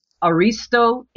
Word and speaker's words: Aristo: Can you say his name again? – Aristo, A-R-I-S-T-O, Vajdani, V-O-J-D-A-N-I. – Okay.